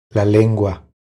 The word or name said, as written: La lengua